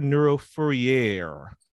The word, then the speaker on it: Neurofourier: It is Dr. Neuro Fourier. – Yes. – Am I saying that correctly? – Yes, you did, actually.